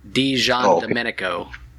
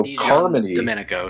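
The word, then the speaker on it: Di Giandomenico: I'm gonna Joshua Williamson didn't even pronounce it right at the WonderCon event. So yeah, it's pretty. It's actually Carmine D. Jean Domenico.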